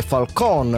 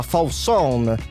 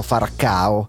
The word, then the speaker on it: Falcao: La pronuncia esatta sarebbe Falcon o addirittura Fausson, ma per i romanisti diventa subito Farcao.